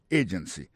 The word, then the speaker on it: agency: Employment agency. Pour mot agency, hein, accent tombé sous premier syllabe là. Agency. Deux autres syllabes qui sont derrière ou faibles.